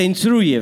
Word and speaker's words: instruye: Te instructa. Is that how you say it? Te da instrucciones. Eso, te instruye.